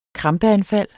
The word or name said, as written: krampeanfald